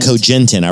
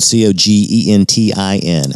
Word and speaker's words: Cogentin: That uh, you Cogentin. I wrote it down. C O G E N T I N.